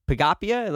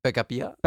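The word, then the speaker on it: Picabia: While you were studying in Paris, you made friends with this guy named Franci Pagapia. Am I pronouncing that right? – No. – No? Okay. How is it pronounced? – Pagapia.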